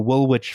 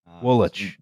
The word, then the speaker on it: Woolwich: Woolwich, you know. – By the way. – Woolwich. – Don't pronounce anything as it's spelled.